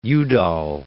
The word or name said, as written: Udall